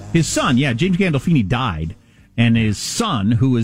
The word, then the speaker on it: Gandolfini: Gallofini? I don't remember his last name. – I don't know. Oh, James Gandolfini? – James Gandolfini, yeah.